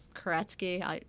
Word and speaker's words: Kuretzky: My Facebook is just my name, Sarah Kuretzky.